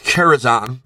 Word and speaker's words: Karazhan: It is pronounced Karazhan.